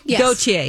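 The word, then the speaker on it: Gotye: I think it's Gautier. – Gautier, yes.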